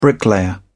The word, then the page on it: bricklayer: British English